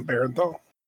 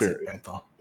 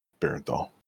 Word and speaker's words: Bernthal: Ghost Recon Breakpoint, which is going to star John Bernthal, who played Shane in The Walking Dead and who was in The Punisher in the Netflix series. – Or John Bernthal. – That's Bernthal. Yeah, Bernthal. My bad.